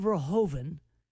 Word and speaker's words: Verhoeven: For anybody who missed it, the World Heavyweight Championship champion, Alexander Usyk from the Ukraine fought Paul Verhoeven from the Netherlands.